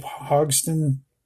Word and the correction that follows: Hodgson: Hodgson, gosh, I can't say his name.